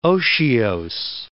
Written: otiose

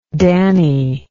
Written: Danny

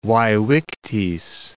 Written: vae victis

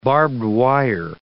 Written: barbed wire

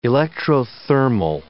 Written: electrothermal

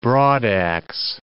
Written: broadaxe

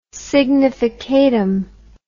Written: significatum

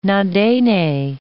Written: Na-Dene

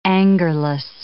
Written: angerless

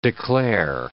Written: declare

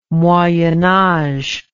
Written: moyen age

